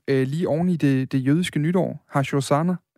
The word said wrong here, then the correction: Rosh Hashanah: øh, hvis jeg udtaler det bare Rosh, Rosh, Rosh, Rosh Hashanah,